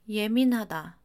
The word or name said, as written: 예민하다